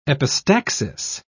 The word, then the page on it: epistaxis: British English